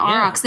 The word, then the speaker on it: aurochs: Another possible option that some people think that the unicorn in the Bible might be is a creature called called an auroch. Is that how you say it? – Oh, yeah, yeah aurochs.